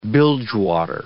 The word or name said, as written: bilgewater